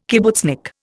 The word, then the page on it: kibbutznik: Indian